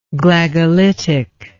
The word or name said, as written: Glagolitic